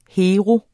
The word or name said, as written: Heroer